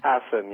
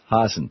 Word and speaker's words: Hassan: Hassan, yes. – Hassan, thank you very much.